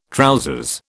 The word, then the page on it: trousers: British English